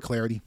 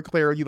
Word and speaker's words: Clardy: I'm just Troy Clarity, but I'm glad to have you here on Twitter at Troy Clarity. The last name is spelled C-L-A-R-D-Y.